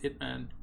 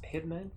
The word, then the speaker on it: Ip Man: What's Ip Man? – Isn't it pronounced Hitman? – Hitman. – No, mate. Ip Man was Ip Man was the this is a video podcast right now.